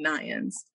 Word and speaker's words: Nions: And Facebook is Pia, spelled the same, Pamela Irene Nions. So that spelling is N-I-O-N-S for the last name.